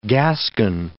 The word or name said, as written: gaskin